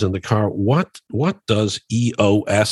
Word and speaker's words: EOS: And it's pronounced EOS.